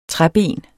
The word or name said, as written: træben